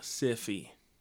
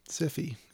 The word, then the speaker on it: Syfy: I even spelled it S-Y-F-Y the way they spell it now. – Yeah, Siffy. – Siffy, yes.